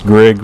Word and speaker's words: Grigg: It's Grigg, rhymes with big.